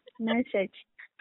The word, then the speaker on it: message: Massage. – Yeah. Not massage, no. Message.